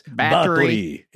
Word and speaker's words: Báthory: Am I saying that right? – Very Americanized, but yes. – Báthory.